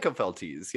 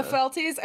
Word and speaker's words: Opheltes: I'm not sure how would you pronounce that? – I think Opheltes, yeah. – Opheltes, okay. So Opheltes